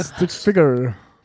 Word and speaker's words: Stick Figure: David Guetta zusammen mit, oh Gott, Alok und Stick Fick, ja, da haben wir es wieder, du kannst es besser aussprechen. – Stick Figure. – Ja. – Figure, also für dich Ficker, sondern Figure.